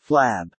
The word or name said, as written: flab